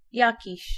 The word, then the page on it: jakiś: [ˈjaciɕ]